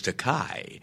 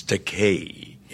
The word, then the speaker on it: Takei: He corrected the pronunciation of his name, but he then called me George Takei, a common mispronunciation of my surname because of the EI. They want to give it that Germanic Einstein, you know, pronunciation, mm-hmm. but it's the vowels in Japanese are pronounced like the Mediterranean languages, Italian, Spanish. It's Takei, E-I. However, there is a Japanese word pronounced Takai with an A, T-A-K-A-I, and that translates into English as expensive.